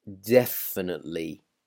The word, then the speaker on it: definitely: That E at the end, where it's T-E-L-Y. We really don't really pronounce it. We don't say telly, then definitely. No, definitely, definitely. So really just remove that E, definitely.